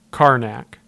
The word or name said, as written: Karnak